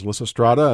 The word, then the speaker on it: Lysistrata: is Lissa Strada, as I pronounced correctly, yes.